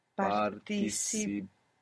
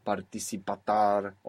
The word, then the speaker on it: participar: We don't want to jump from participate to participar because maybe we end up putting some extra sound, participatar, or something like this.